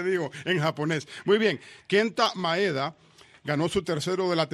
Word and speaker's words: Kenta Maeda: El pitcher japonés, y lo quiero pronunciar bien, porque sin querer lo he pronunciado mal, Kenta Maeda. ¿Ok? Lo pronuncié bien esta vez.